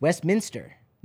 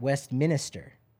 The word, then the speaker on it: Westminster: It's Westminster, not Westminster. It's not minister. – Oh, no. – It's Westminster.